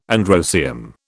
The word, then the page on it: androecium: British English